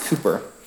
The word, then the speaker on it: Cowper: His last name is spelled C-O-W-P-E-R, but it's pronounced Cooper.